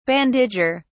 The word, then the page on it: bandager: British English